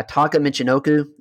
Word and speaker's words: Taka Michinoku: I say this name wrong, this light heavyweight guy. Taka Michinoku, is that right? – That's right.